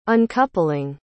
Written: uncoupling

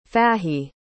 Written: Fahey

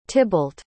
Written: tibalt

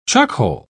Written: chuckhole

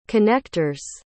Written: connector's

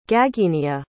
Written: Gagenia

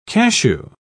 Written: cashew

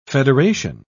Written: federation

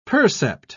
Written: percept

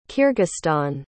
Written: kyrgyzstan

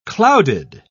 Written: clouded

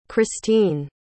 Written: cristine

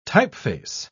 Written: typeface

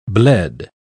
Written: bled

